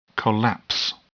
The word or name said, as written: collapse